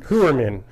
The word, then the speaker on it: Heuermann: So a Rex Heuermann, is that how they are pronouncing it? – Heuermann.